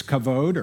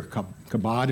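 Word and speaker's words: kavod (כָּבוֹד): Well, in the Old Testament, the word glory is kavod, or kabod, if you want to pronounce it that way.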